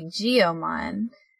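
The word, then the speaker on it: Giomon: And I found that really annoying, because in English, a G followed by an I... it's usually pronounced more like an E, so it should be Geomon. They should have used a Y for Geomon.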